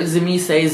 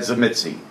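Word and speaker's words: Tzimisce: So there's so many different ways, like Zemise, Zemetse. There's all kinds of weird ways of pronouncing. – Well, I got it from the writer. – That's great. – The guy that created the clan. He said Zemitsi.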